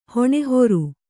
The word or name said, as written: ಹೊಣೆಹೊರು